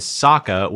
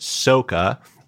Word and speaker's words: Sokka: Not only did he mispronounce that, the character played by Jackson Rathbone, whose name in the cartoon is Sokka, was pronounced Soka.